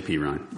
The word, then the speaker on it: Perine: It's Perrine, right? Samaje Perine. P. Ryan. Sorry. Yeah. Perine. My bad.